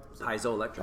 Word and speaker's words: piezoelectric: So the other type is the, how did I pronounce that correctly? The piezoelectric? – Piezoelectric. – Piezoelectric, okay. Thank you.